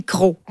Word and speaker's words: Crocs: Alors, sans aucun doute, on parlerait de crocs. Ah. André, lui, nous dit, de Longueuil, il dit « Je privilégie toujours la langue française, si souvent malmenée. Alors, c'est crocs comme prononciation.